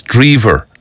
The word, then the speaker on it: Drever: It's spelled D-R-E-V-E-R. That's the thing. You see, like with them Americans, sure, look, it's the same with Callaghan, isn't it? Ian Callaghan, who played for Liverpool. So similarly, Chris, D-R-E-V-E-R, is pronounced Chris Drever, and he's touring Ireland, I think, all next week, there or thereabouts.